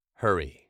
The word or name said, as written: hurry